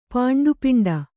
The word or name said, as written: ಪಾಂಡುಪಿಂಡ